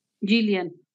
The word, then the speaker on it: Gillian: Y finalmente, eh, aquí no sé cómo se pronuncia. ¿Es Gillian? Gillian. – Gillian, ¿no? – Gillian. Gillian Anderson hace a Eleanor Roosevelt y tiene, le hacen usar una prótesis en la boca.